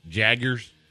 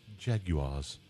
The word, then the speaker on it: Jaguars: I think I'm going to go with the English pronunciation. – Do it. – No Jaguars. No Jaggers. – Make it sexy. – I'll go with Jaguars.